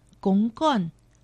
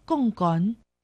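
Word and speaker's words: 公館: 在今天节目中介绍客家文化重点发展区苗栗县公馆乡，客家话四县腔发音公馆，海陆腔发音共馆，欢迎听众朋友们一同收听。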